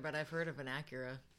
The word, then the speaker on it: Acura: Is it an Acura or an Asura? – Mm. It's A C U R A. It's a vehicle. – Acura? – Acura. Wow, I fucked up hard.